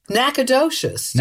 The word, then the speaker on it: Nacogdoches: N-A-C-O-D-O-C-H-E-S. You think it's like Nacogdoches or something. How do you say this? – Nacogdoches. – Nacogdoches. – You were pretty much there.